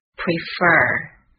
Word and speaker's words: prefer: I 饱满的双元音 I prefer p r e f e r prefer。 P-R, pr, e, I, pre, f, e-r, er, fer, prefer. 听一下它的重音在哪里 ?prefer, 在 fer 这个位置 ,prefer. T, T-E-A, E-A 的发音是长音 E, T, 表示茶。